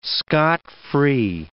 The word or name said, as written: scot-free